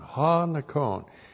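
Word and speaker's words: Ha Nikon (ὁ νικῶν): The definite article Ha plus N-I-K long O-N. Ha Nikon.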